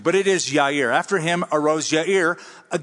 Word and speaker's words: Jair: After him, so after those 23 years of adjudication, after him arose Jair. That's how we usually pronounce it. That is a mispronunciation. The Hebrew pronunciation would be Yair.